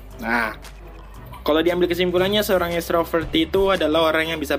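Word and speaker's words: ekstrover: Kalau berdasarkan KBBI itu dibacanya extrovert. E X E K S T R O V E R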